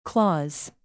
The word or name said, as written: claws